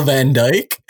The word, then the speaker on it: Van Dijk: Is it Van Eyck? – I don't know. Say his name again. – Nope. I don't even know how CJ Van Eyck. Virgil Van Dijk.